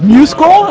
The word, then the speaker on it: MuseScore: And did I mispronounce the name of the project? – The name of the project is MuseScore. I think you were right.